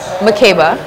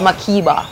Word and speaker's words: Makeba: But okay, so my name is Makeba, the proper pronunciation. I was named after Mayor Makeba, who's like an activist and a South African singer. Look her up because she's powerful, legendary, right? – Yeah. – But my parents are Jamaican, so it's Makeba. It's how they pronounce it.